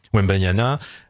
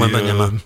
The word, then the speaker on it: Wembanyama: maintenant c'est Wembanyama. Ah, j'ai écorché le nom. Dans le foot, c'est Wembanyama. – Voilà. Merci.